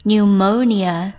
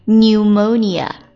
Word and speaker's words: pneumonia: 那急性肺炎叫做 pneumonia， p 也是一个废物不发音 pneumonia， p 和 e 都不发音 pneumonia， pneumonia，急性肺炎。